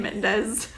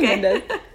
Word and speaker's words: Mendes: We say Sam Mendes. Is that how you say it? – Yeah, I say Mendes.